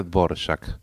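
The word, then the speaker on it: Dvořák: Dvorak, que tiene un acento raro en la R, ¿no? Y otro parecido al nuestro en la A, se pronuncia Dvorak.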